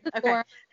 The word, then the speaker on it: Sephora: she used to work for Sephora. Mm-hmm. Sorry if I'm mispronouncing that. – No, that's right. Sephora.